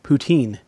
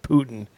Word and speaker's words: poutine: And as another Canadian, I just have to say something, that it's pronounced poutine. The way you said it, it almost felt like we were, it almost sounded like the Russian version, where it sounded like you said Putin.